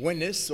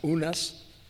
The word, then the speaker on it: Unas: It may correspond to the name Winnis, or usually pronounced Unas, by those who transliterate it from the Egyptian.